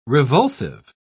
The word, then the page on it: revulsive: British English